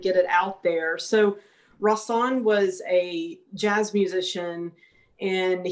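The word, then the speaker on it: Rahsaan: Rasan Roland Kirk. Is it Rasan? – Yeah, you got it right. A lot of people mispronounce his name, but that's it exactly.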